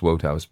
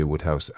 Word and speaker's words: Wodehouse: Somebody tried to correct my pronunciation and say, no. It's written Wodehouse, and they thought it should be pronounced Wodehouse, but it shouldn't.